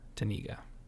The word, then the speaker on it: Tanega: T-A-N. It's probably like Taniga. T-A-N-E-G-A. Taniga. That's Norma Taniga.